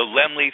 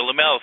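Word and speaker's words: Laemmle: It opens tomorrow in Los Angeles at the Lemley Theater, or the Lamel Theater, depending on which way you like to pronounce it.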